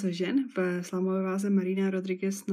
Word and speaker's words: Rodriguez: A na druhé straně Marina Rodriguez, nebo Rodriguez by se ho správně měla vyslovovat, má na kontě tři výhry v řadě a je tak trošku na koni, na cestě k možné titulové šanci.